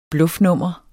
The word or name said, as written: bluffnummer